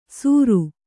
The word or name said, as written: ಸೂರು